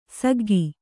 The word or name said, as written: ಸಿಗ್ಗು